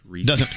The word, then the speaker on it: Reese's: It doesn't rhyme with feces. – It's Reese's pieces.